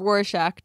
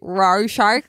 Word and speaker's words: Rorschach: But I thought it like, I feel like it's sort of a, how do you say Rorschach test? – Cucumber? It's Rorschach test. – Escalade. Sorry, that's how we pronounce it in España. But in España, we say "raw shark."